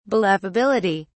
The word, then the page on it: believability: British English